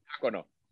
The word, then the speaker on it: Jacono: creo que se llama Andrew Jacono. Lo pronunciamos, lo pronunciamos bien. Jacono.